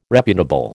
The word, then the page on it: reputable: American English